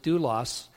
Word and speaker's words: doulos: This is the word we pronounce it, doulos, and it really means a slave.